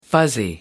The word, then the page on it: fuzzy: American English